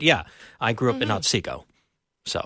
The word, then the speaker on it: Otsego: Otsego, Minnesota. I don't know. Am I supposed to? Is the T silent? – No. That's right. You said it right. I grew up in Otsego.